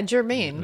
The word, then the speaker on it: Germain: How do you pronounce G-E-R-M-A-I-N? – Yeah, Germain. Right? – Just Germain? – Yeah, Germain. – Okay. – Like Saint Germain? – We have Tabitha Saint Germain voicing Scary Godmother.